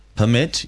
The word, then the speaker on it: permit: What's the difference in pronunciation between permit used as a verb and permit used as a noun? – The difference in pronunciation between permit used as a verb and permit used as a noun is that, as a verb, the stress is on the second syllable, whilst as a noun, it's on the first.